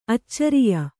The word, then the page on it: ಆಚಾರಿಯ: ācāriya